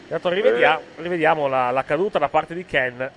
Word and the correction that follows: Kane: di Kane, chiedo scusa, Ken è un altro giocatore. – Lapsus è strano questo, No, già stamattina avevo problemi con la pronuncia, cioè, mi ricordavo che c'era... Ricordavo che c'era Kane in campo, mi viene naturale pronunciarlo Ken.